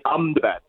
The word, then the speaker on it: Ahmedabad: Actually, they're pronouncing it Ahmedabad, so they skip the E in the Ahmedabad bit and just go Ahmedabad.